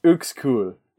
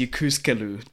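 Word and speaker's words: Uexküll: According to YouTube, it's Ukskul. – Ukskul. – The anime definitely pronounces it as Yukuskelu, though. – Mm-hmm. – So we're going with that one.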